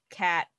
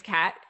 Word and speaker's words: Cat: My name yeah. is Cat, C A T.